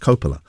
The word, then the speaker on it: Coppola: Although, actually, I think her name is pronounced Coppola. So I've been getting her name wrong this whole time.